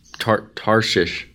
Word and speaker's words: Tarshish: Tarshish. I can't say that for some reason. – You said it right.